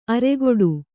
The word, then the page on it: ಅರೆಗೊಡು: aregoḍu